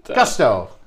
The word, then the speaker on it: gusto: You got gutso. Gusto. I said that wrong already.